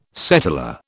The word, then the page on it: settlor: British English